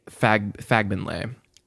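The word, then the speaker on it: Fagbenle: You're also getting, and I looked up the pronunciation. It is O.T. Fagbenley.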